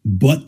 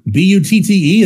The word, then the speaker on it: Butte: One was initially recruited as a linebacker, and the other was a rando JUCO that Brian Harsin found from Butt Community College in California. – Oh, that's an actual community college? – BUTTE, that's how it's pronounced, butt, right? Sometimes throw an E on the end, pronounce it butte, but.